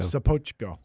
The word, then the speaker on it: Sopocko: I, I always think of him as Father Sopocko, but I'm saying it wrong. So Sopocko. Sopocko.